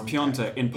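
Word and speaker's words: Piątek: Every commentator says Piontek. – It's Piontek. In Polish, there's some accent on there. Someone Polish told us this today. There's an accent on the A, which makes it sound like Piontek.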